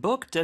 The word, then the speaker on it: booked: I've booked a table for you at 7pm. – 네, I've booked라고 했는데요. Mm-hmm. 스펠링은요? – Could you hear the kt at the end? Booked. B-O-O-K-E-D. – 맞아요. B-O-O-K-E-D.